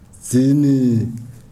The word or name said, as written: dzini